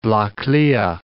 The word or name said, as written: Baile Atha Cliath